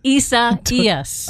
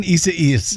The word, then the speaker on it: Isaias: Isaias? – Isaias. – I see what you're doing. You're laughing at me. Hurricane Isaias.